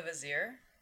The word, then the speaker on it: vizier: You mean the vizier? – Is that how it's pronounced? – That's what they say in the movie. You said visor, like the thing that poker players wear. – You're correct. I did mean vizier.